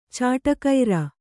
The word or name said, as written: ಚಾಡಿಕೋರ